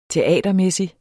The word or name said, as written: teatermæssig